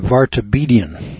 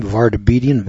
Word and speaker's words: Vartabedian: Let's see if I can pronounce his name. Vartabedian. It's an odd name. Vartabedian. V-A-R-T-A-B-E-D-I-A-N.